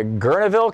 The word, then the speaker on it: Guerneville: Gene in Guerneville, California.